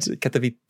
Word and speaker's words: Katowice: Yeah, the Spodek Arena in Katowice. – In Katowice. And, Katowice.